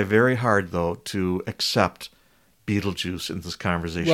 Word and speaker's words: Betelgeuse: I swear up and down, and there's a couple other internet denizens that agree with me, that before the movie Beetlejuice came out, it was commonly pronounced Beetlegeese.